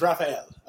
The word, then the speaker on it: Raphael: My full given name is Rafael, spelled P-H, so R-A-P-H-A-E-L. I'm actually named after my great-grandfather, who is Irish. So because of the way they pronounce it, they would say Raphael.